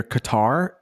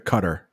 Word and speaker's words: Qatar: Sometimes you'll hear Qatar and then sometimes you'll hear Qatar. Yeah. Both pronunciations.